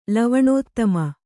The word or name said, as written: ಲವಣೋತ್ತಮ